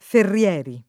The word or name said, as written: Ferrieri